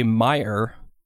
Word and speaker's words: Meyer: Now we've got another email from Robert August De Meyer, rhymes with fire, is what he says.